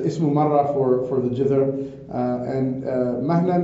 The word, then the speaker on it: مهنة: So this word مهنة is usually in spoken Arabic pronounced as mihna. Al-Asma'i, who was one of the great linguists of the Arabic language, he says that it's the word is mahna, it's not mihna.